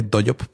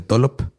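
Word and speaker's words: Dollop: se escribe con doble L, DOYOP, pero se dice DOLOP que me gusta que algunos están siendo el Lolop.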